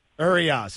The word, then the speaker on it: Urias: Urias, is that the Dodgers pitcher? Did I pronounce it correctly, Tim? – Yes, he's pitching tonight, Stugatz.